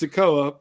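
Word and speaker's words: Sikoa: It's Sokoa, but playing along for shits and giggles.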